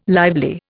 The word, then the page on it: lively: Indian